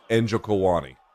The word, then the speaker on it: Njokuani: So the way they say it on the broadcast, so again, I'm sure I'm saying it wrong too, but the way they say it on the broadcast is Njokuwani.